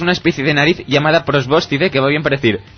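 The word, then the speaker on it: probóscide: Prosbóscide. – ¿Dónde está el acento? Para no cagarla como antes. – En la segunda O.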